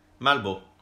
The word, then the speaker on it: Melbu: Eller Melbo, Melbo. som det uttales.